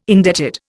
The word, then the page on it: indebted: Indian